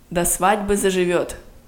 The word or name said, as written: до свадьбы заживет